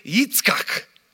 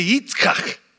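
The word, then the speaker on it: Yitzchak (יצחק): Okay, the name Isaac in Hebrew, everybody say Yitzchak. Everybody say Yitzchak. Like Yitzchak, all right? Yitzchak is how you say Isaac in Hebrew.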